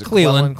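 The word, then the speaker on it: Clelin: And from his very first pass, when he was pressured by Cleland Farrell, or Cleland, how do you pronounce his name? – It's Cleland.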